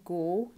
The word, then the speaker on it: Goole: So obviously, if you remember, as I mentioned in the first video, Gool, G-O-O-L-E, his name, rhymes with Ghoul, G-H-O-U-L, which is an evil spirit, but also a fairly scary spirit, a ghost.